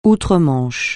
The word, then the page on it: outre-Manche: francais